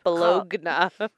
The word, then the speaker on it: Bologna: You know, the Italians pronounce that hard G. – Yep. – Bologna.